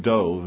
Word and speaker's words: Dov: Just a first thing about the name. It's in Hebrew, it's pronounced Dove. If anyone read the book Exodus, it's one of the main characters. But Dove means bear.